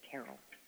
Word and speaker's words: Terrell: I think most of us pronounce it as Terrell, but I had a researcher go to the library at Howard to get some of her papers, and she says that they pronounce it Terrell.